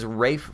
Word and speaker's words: Ralph: I mean, the guy's name is Rafe Fiennes, but it's spelled Ralph.